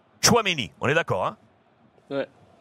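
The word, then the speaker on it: Tchouaméni: Et euh, c'est votre joueur de, Monaco, que je ne sais pas comment le prononcer, mais Chouameni. Chouameni.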